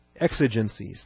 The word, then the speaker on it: exigencies: Did I say that right? Exigencies. Thank you.